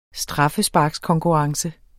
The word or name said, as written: straffesparkskonkurrence